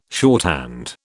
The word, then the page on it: shorthand: British English